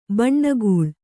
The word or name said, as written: ಬೆಣ್ಣೆಗಳೆ